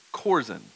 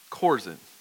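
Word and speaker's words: Chorazin: And the H is silent, and it's chorzen, okay? Chorzen. So just remember that when we get to that one, chorzen.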